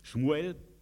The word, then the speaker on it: Schmuel: Schmuel, hier wird es hebräisch ausgesprochen.